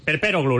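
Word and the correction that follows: Perperoglou: l'he puesto mal el acento. – Sí, sí. Vaya, en este caso casi. Es drújula, ¿eh? Pero bueno, tenías cuatro o cinco sílabas. Bueno, pues la antepenúlti, vamos, es drújulo.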